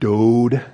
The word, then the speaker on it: dowd: Looks like, if you know anybody named Dowd. It's actually pronounced dode with a long O sound.